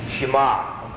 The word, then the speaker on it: Shema: Shema. Okay. S-H-E-M-A is the way we would spell it.